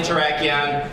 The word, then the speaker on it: Terrakion: So some of you guys said it right. It's Cavalion, Terrakion, Virizion, and Keldeo.